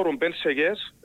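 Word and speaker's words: Rubén Sellés: Κοίταξε, η αλήθεια είναι ότι ο Ρουμπέν Σεγιές, έτσι μάθαμε και το σωστά πώς θα προφέρουμε το όνομα τεμπόριμο του.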